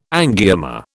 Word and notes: angioma: British English

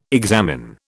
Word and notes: examine: British English

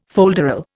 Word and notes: folderol: Indian

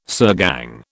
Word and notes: sirgang: British English

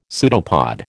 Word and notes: pseudopod: American English

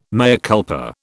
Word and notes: mea culpa: British English

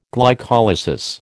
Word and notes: glycolysis: American English

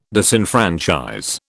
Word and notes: disenfranchise: British English

